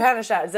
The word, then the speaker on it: Upanishads: Upanishads.